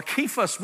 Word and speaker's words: Cephas: I am of Cephas, or Cephas would be the pronunciation.